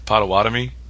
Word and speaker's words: Potawatomi: Potawatomi.